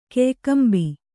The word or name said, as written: ಕೆಯ್ಕಂಬಿ